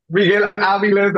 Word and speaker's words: Miguel Aviles: So did I say it right? Because, you know, I've been practicing my L's. My L's is horrible. – Miguel Aviles, that's beautiful.